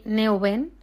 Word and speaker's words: Nouwen: Hemos escuchado a Hakuna Group Music y esto es lo que sintió el protagonista de esta noche, el padre Henry Neuben. Se escribe Nowen para las personas que unos saben inglés, otros no.